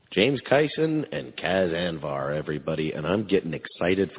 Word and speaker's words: Cas Anvar: So thank you once again, James Kyson, Cas Anvar. Well pronounced, by the way, I think. – You're good with Canadian names. – I am horrible on Canadian names, so I'm. – You nailed it.